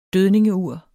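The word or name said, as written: DØDNINGEURE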